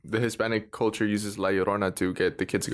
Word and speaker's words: La Llorona: Like what you were saying with like La Llorona.